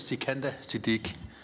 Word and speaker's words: Sikandar Siddiq: Ja, navnet udtales Sikandar Siddiq.